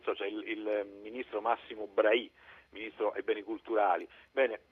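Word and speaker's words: Bray: e quindi la pronuncia è senz'altro Brahi, come del resto preferisce lo stesso ministro, l'ho letto nel suo blog.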